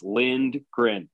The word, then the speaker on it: Lindgren: It's not even on the pronunciation guide because it is as it sounds, Lindgren.